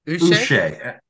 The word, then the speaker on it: Uche: How do you pronounce his last name? You would maybe know. Is it Josh Uche? – Uche. – See, I think that's how it is.